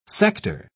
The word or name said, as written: sector